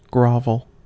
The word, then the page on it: grovel: American English